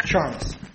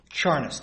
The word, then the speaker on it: Charnes: Well, tell me how to say it. – Charnes. – What is it? – Charnes, two syllables.